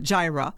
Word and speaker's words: Jireh: Le film montre que le Jaira Gospel, il faut dire que ça s'écrit le J-I-R-E-H. Alors, on est porté à dire J-I-R-E ou J-I-R-E, mais c'est Jaira Gospel Choir.